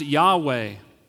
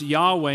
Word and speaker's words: Yahweh: It's pronounced Yahweh. It's pronounced Yahweh, and it's based on the four Hebrew letters, YHWH, Yahweh.